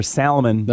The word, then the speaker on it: salmon: Also, pronouncing the L's in a word like tortilla. – Oh, I hear people do that. – Give me a tortilla. – Quesadilla. – Or salmon.